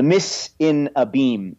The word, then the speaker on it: mise en abyme: the mise en abyme, A-B-Y-M-E there at the end there.